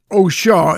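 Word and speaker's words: Au Chat: It's on a package called Donald Byrd Plays Oshaw, is the way it's pronounced, apparently, on the Fresh Sounds label.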